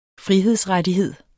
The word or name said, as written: frihedsrettighed